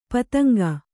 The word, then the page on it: ಪತಂಗ: Kannada